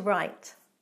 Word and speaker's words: write: R-I-G-H-T. But right with a silent W means the verb to actually put pen to paper and make words on the paper. To write.